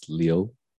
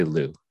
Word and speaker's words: Liu: I guess technically in Chinese, it's Liu. But good to know. – But yeah, in English we say Lou.